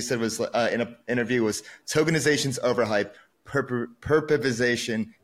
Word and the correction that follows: perpification: is underhype. Did I say that right? – Perpification.